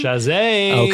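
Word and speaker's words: Chasez: Shazay.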